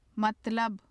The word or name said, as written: matlab